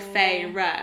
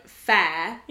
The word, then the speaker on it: Feyre: But, so, first time round, I must have missed the sentence where it says, like, Elaine pronounces my name and it's like Feyre. – Oh, yeah. – So I must have missed that because I pronounced her name in my head Fair, as in Fair, instead of Feyre.